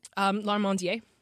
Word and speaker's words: Larmandier: Larmandier.